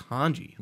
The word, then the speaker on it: Hanji: My friend thought Hanji, like, I guess he thought her name was Hanj in like the sub because mm-hmm. they might say it differently. I'm like, no, it's Hanji. Like they say Hanji.